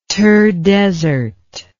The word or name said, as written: thar desert